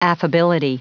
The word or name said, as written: affability